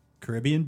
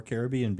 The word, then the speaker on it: Caribbean: So moderate resorts are Caribbean Beach or Caribbean Beach, depending how you pronounce it, Coronado Springs, Port Orleans, French Quarter, Port Orleans Riverside, or the Cabins at Fort Wilderness Resort.